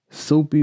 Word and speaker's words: Sope: But his real name is Soapy, or S-O-P-E. Last name Dirisu, D-I-R-I-S-U.